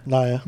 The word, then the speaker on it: Nia: Nia Jax is Nia or Nia? – Nia. – Nia. See, I always pronounce it wrong.